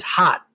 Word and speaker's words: Hat: Well, his name is Hot, literally H-A-T, but it's not pronounced Hat. It's Hot.